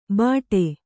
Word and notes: ಭಾಟೆ: Kannada

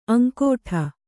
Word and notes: ಅಂಗಾಡಿ: aŋgāḍi

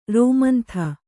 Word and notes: ರೋಮಂಥ: rōmantha

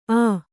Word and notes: ಆ: ā

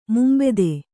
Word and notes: ಮುಂಬೆದೆ: Kannada

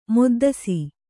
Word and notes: ಮೊದ್ದಸಿ: moddasi

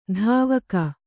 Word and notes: ಧಾವಕ: Kannada

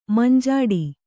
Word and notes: ಮಂಜಿಟಿ: manjiṭi

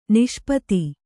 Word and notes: ನಿಷ್ಪತ್ತಿ: Kannada; niṣpatti

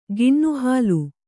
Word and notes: ಕೆನೆಹಾಲು: kene hālu

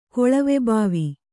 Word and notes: ಕೊಳವೆಭಾವಿ: koḷave bhāvi

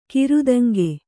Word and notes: ಕಿರುದೆಂಗು: kannada

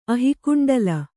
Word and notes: ಅಹಿಕುಂಡಲ: Kannada

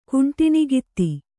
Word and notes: ಕುಂಟಣಗಿತ್ತಿ: kuṇṭaṇa gitti

